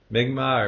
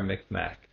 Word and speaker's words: Mi'kmaq: Mi'kmaq or Mi'kmaq. It's pronounced both ways.